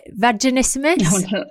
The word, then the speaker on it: vaginismus: Vaginismus? – Yes.